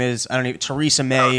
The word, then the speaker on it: Theresa: Are you sure it's not pronounced Theresa? I wasn't sure. Did I lose you there? – Theresa. – No, I'm here. Theresa. I was kidding. It's clearly not Theresa. So anyways.